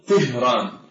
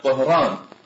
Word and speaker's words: طهران: فمثلا في كلمة طهران لا يقولون طهران وإنما يقولون تهران في اللغة الفارسية، ولكن العرب مثل هذا النطق صعب عليهم أن يأتي الحرف المكسور الصوت الساكن ثم يأتي بعده حرف مهتوت وهو الهاء لضعفه، فيرجعونها إلى الأصل فيقولون طهران. فكلمة فسطاط أيضا عندما يجمعونها يقولون فساطيط بالطاء ولا يقولون فساطيط، والكلمة معربة كما ذكرت.